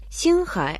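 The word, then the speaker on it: Синхай (星海): Синхай означает «море звезд». Син – это звезды, произносится в первом тоне. А Хай означает «море», произносится в третьем тоне.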